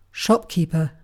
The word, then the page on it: shopkeeper: British English